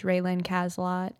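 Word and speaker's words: RaeLynn Cazelot: But for right now, they could contact me via my personal Facebook page, Raylynn Caslott.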